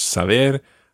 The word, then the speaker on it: saber: All the infinitives always are stressed on that last syllable. So it's saber, conocer.